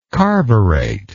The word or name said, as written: carburate